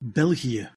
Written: belgie

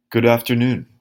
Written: good afternoon

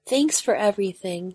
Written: thanks for everything

